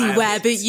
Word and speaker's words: wabbit: You crazy wabbit. You wabbit. – Spell this shit for me. – Wabbit. Right. It's pronounced just like Elmer Fudge pronounces it, except wabbit.